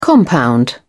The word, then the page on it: compound: British English